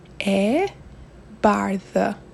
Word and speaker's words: e bardhë: So, how do you say the color white in Albanian? We say e bardhë.